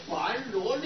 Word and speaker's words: 般若: 音译该找这个般若两个字来译这个梵语啊，为什么找这个般若两个字来译这个梵语，还叫我们鸟住口要念成般若，什么道理呢？有人怀疑，这个也要了解。这个般若两个字啊，是我们现在念的音声。